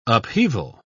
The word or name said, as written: upheaval